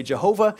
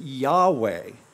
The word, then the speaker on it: Yahweh: Now, in English, we often say Jehovah. In Hebrew, they didn't have the phonetic Jah, so they would not have said Jesus. They would have pronounced that differently. They would not have said Jehovah. They would have pronounced it Yahweh.